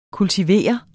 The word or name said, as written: kultiveret